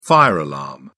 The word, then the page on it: fire alarm: British English